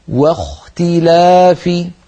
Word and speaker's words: واختلاف: فننبه على كلمة واختلاف في الآية 64 بعد المئة، الآية الأولى التي قرأناها، واختي واختلافي، لابد من أن ننطق الواو مرققة، فلا نقول واخ واختلافي، بل نرقق الواو ونفخم الخاء هكذا واختلافي.